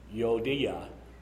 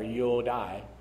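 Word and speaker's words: Euodia: So now I appeal to you, Yodia, or Yodai, you can see it there, and Syntyche is how that's pronounced.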